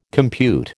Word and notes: compute: American English

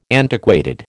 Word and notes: antiquated: American English

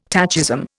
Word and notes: tachism: Indian

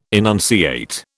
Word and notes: enunciate: British English

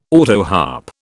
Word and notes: autoharp: British English

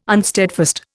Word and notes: unsteadfast: Indian

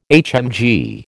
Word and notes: HMG: American English

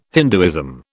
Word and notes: Hinduism: British English

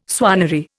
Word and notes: swannery: Indian

